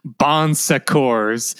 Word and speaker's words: Bon Secours: And I know it's French, but this is an English, an American company, so you know it's pronounced Bon Secours.